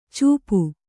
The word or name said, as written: ಚೂಪು